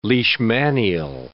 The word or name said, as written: leishmanial